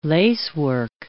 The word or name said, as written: lacework